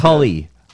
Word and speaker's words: challah: You're saying it wrong. – Challah. – Challah. And challah. The old Jew. No. You're also even then. Old Jew way is chully.